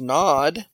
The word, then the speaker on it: Nod: So we might say in English Nod, but in Hebrew it's pronounced Nod.